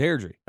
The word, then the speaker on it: Airdrie: It's Airdrie. – It's Airdrie.